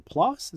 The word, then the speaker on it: PLOS: So there was a study that was published in PLOS. Is that how you pronounce it? – I think that sounds right.